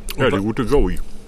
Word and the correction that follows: go-e: Also, Go Joey. Ach so, spricht man das aus? – Go-E. – Go-E, ja, ich dachte Joey.